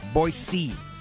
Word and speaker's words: Boise: I think it's soft S. Boise, Idaho, which there is a movement for them to change their name, as the Cleveland Indians have done, to Person C.